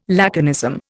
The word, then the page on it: laconism: Indian